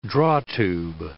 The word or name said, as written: drawtube